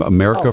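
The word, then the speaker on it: America Ferrera: America. Yeah. Is how you say her name. America Ferrara.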